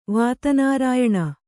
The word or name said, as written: ವಾತನಾರಾಯಣಿ